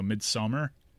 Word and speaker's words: Midsommar: It's called Midsommar, S-O-M-M-A-R, which I think is just Swedish for summer. So Midsommar.